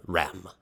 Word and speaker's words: Reims: Uh, Rem. I don't think you pronounce the S. R E I M S. – Rem.